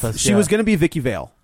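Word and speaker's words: Vicki Vale: It's pronounced Vicky Vale. Vicky Vale.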